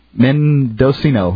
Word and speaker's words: Mendocino: So, when motion-detecting cameras at the Modiceno courthouse in... Where is this? – Mendocino? – Mendocino? – California? – Mendocino? – Spell it. – Mendocino, yes.